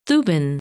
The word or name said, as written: Thuban